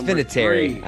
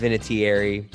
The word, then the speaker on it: Vinatieri: It's Vinatieri. I know it's pronounced Vinatieri, but like, get over yourself. All right, it's Vinatieri.